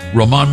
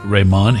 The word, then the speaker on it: Roman: He does not pronounce it Ramon. He's not R-A-M-O-N. He's R-O-M-A-N. He's Roman Martinez, just so you know.